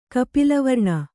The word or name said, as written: ಕಪಿಲವರ್ಣ